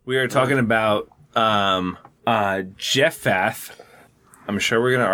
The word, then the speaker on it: Jephthah: It's definitely Jephthah. – No, it's a silent H. – It's a PH. It's Jephthah. – It's Hebrew. – So it's got two silent H's.